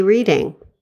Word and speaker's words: Reading: Oh, the city of brotherly reading. – Reading, Pennsylvania. I believe it's pronounced Reading. – Oh.